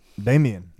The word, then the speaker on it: Damian: Damian. – Damian. Said, that's what I said. – You said Damon. – Nah, I played back. Listen to what I just said. I said it correctly this time.